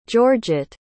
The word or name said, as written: georgitte